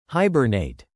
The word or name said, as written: hibernate